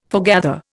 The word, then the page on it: foregather: Indian